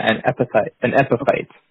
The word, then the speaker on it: epiphyte: Epiphyte, is that how you pronounce it? A epiphyte? – Yeah, an epiphyte.